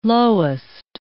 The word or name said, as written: lowest